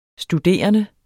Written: studerende

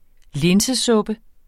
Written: linsesuppe

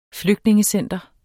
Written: flygtningecenter

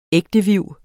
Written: ægteviv

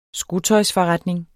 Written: skotøjsforretning

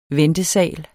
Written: ventesal

